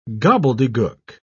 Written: gobbledygook

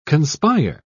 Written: conspire

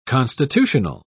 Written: constitutional

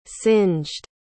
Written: singed

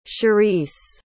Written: charisse

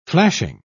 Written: flashing

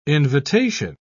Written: invitation

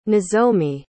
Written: nozomi

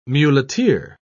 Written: muleteer

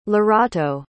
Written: lerato

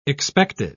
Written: expected

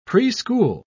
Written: preschool

